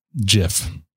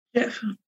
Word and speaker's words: GIF: According to a handful of blogs and websites, Wilhite and a graphics file formats FAQ page from 97 argue that the proper way to pronounce the format is GIF. – GIF. – Oh, thank you. – Like the peanut butter. – Yes. – Yeah. – It's fucking embarrassing! – So there you go. Soft G.